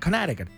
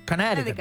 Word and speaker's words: Connecticut: Que se dice de otra manera en Estados Unidos, que es muy difícil, como que no pronuncian la C ni nada. Connecticut, Connecticut.